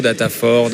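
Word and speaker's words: Data4: Donc moi je prononce en fait Data 4, en fait avec le, puisqu'on est très local, on dit Data Quattro, Data 4, Data Sphere.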